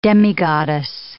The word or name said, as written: demigoddess